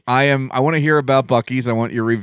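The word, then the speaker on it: Buc-ee's: And then it has the Bucky's logo, which is, I guess it's a, what is that? – It's a beaver.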